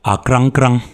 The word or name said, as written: Akrangkrang